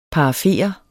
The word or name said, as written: PARAFERE